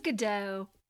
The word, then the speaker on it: Gadot: But they're making a movie about her and Gal Gadot. Did I say that right? – I think it's Gadot.